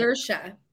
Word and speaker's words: Saoirse: Saoirse, is that how you say it? – Saoirse. – Saoirse, okay. All right, that was way off.